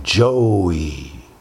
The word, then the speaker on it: Joey: by the long O and the elongation of the E-Y, Joey.